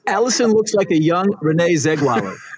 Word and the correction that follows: Zellweger: I think she pronounces her name Zellweger, for whatever that's worth.